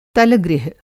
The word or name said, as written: तलगृह